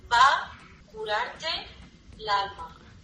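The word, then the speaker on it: pa' curarte l'alma: What do you want to say? – Pa-cu-cur-er-tel-alma. – You can say pa curarte l'alma.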